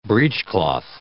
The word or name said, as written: breechcloth